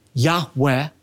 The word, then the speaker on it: Yahweh: In fact, when God revealed to Moses His personal covenant name, composed of the four Hebrew letters, Yud Heh Vav Heh, oftentimes pronounced a breathy Yahweh, the tense of God's sacred name, Yud Heh Vav Heh, carries with it the idea of continuous, unfinished action.